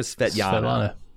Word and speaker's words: Svetlana: Svetlana. Svetlana. Is that her name or Svetlana? – Svetlana. – Okay. Yeah. I wasn't sure if the L's are pronounced as Y's over there.